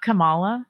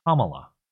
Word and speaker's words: Kamala: It's not Kamala. – It's Kamala. Like comma, the punctuation, la.